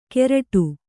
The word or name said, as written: ಕೆರಟು